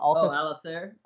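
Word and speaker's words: Alcácer: Alcacer, is that how you say his name? – Alcacer. – Sorry, I butchered his name.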